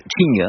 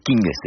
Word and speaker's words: Qing: Donc vous dites Qing, hein, pour King, c'est Qing, la prononciation chinoise, à laquelle on n'est pas contraint d'ailleurs.